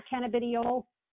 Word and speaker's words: cannabidiol: Am I pronouncing that correctly? Is it cannabidiol, cannabidiol? – It's cannabidiol. You can pronounce it. We can. – Cannabidiol. Okay. – People often mispronounce it.